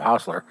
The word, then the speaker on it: Hausler: And it's Chris Clemmons and Bob Hausler.